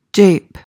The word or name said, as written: jape